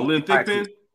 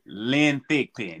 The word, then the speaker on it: Thigpen: Oh, Lynn Thigpen. – I'm sorry. – Lynn Thigpen. – Lynn Thigpen.